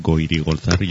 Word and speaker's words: Goirigolzarri: Te recomendamos que te aprendas la pronunciación de un nombre, Goirigolzarri, porque una vez que lo tengas ya controlado, mira, Goirigolzarri, yo me lo he preparado,